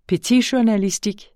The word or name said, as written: PETITJOURNALISTIK